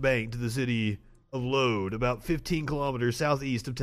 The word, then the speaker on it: Lod: Lod.